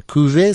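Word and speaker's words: cuvée: «Кюве Санкт-Петербург». – «Кюве»? – «Кюве»? Да. – «Кюве»? Как пишется? «Кювет»? – Нет, «Кюве».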